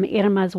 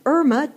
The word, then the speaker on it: Irma: My real name, this four-letter word, I-R-M-A. I know, it looks like Irma to some of you. And that is one pronunciation of this name. But the name that my parents gave me is Irma.